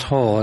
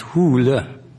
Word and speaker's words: Hole: No, that's actually the name, but it's not pronounced Hole, it's pronounced Hule.